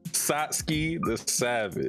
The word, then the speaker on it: Satsuki the Savage: Satsuki the Savage.